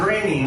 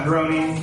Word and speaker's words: Groening: So, The Simpsons predicted the Donald Trump presidency because obviously Matt Groening, that's how you say his name. It's not Groening, it's Groening.